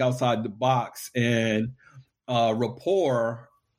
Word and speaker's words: Rapport: So my very first podcast that I created was it's spelled Rapport, R-A-P-P-O-R-T, but it's pronounced The Minority Report.